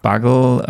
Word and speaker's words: Bugle: Buggle, ähm, ich glaube, es wird ganz anders ausgesprochen. Daily, ich glaube, die sagen immer Daily Bugle, Bugle oder Bugle, meine ich.